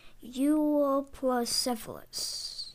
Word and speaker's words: Euoplocephalus: So it said Euoplocephalus. – Euoplocephalus.